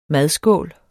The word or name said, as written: madskål